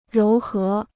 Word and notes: 柔和: róu hé

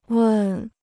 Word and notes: 问: wèn

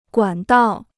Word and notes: 管道: guǎn dào

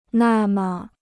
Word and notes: 那么: nà me

